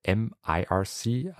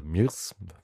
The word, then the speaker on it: mIRC: Also wenn ihr das mal austesten möchtet, IRC, speziell MIRC, also oder MIRS, höre ich auch immer recht häufig als Aussprache.